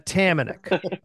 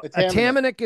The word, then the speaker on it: Atamanik: So Atamanik is how you say it. Atamanik. – Okay. – Now that's Canadianized. So it's Ukrainian. So it should be Atamanyuk. That's the way it should be pronounced.